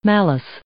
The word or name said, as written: malice